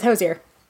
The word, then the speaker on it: Hozier: And he goes, the easiest way for me to describe it is it rhymes with cozier, which means I have been saying it right. It is Hozier.